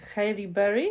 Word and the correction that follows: Halle Berry: jak się wymawia, nigdy nie wiem, Halle Berry Y,